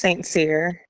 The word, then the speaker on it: St. Cyr: Yeah, my last name's St. Cyr. It's like a Haitian, French, Caribbean last name that I married into.